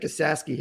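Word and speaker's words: Esasky: I saw Nick Isasky hit his 30th home run there in 88, I think. – Is that how that guy's last name is pronounced? – Yes.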